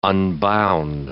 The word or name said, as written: unbound